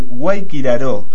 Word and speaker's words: Guayquiraró: Exactamente, los ríos más importantes, los ríos principales de esta pendiente son el Guayquiró. – Sí. – Perdón, el Guayquiraró es el nombre. – Sí, sacaste una sílaba, vos querías hablar menos, es eso.